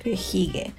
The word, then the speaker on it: hygge: que se escribe H-Y-G-G-E. Hige. Así que el Instagram de esta tienda es Sabores a Granel y Hige, H-Y-J, y g e Ahí tiene unas trufas exquisitas también, exquisitas.